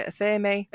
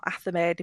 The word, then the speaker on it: athame: Your black handled knife is commonly called your athame or athame, depending on how people pronounce it, and that is your knife that does not cut anything.